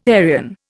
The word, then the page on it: Aryan: Indian